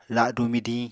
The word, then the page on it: ladu midiñ: Apatani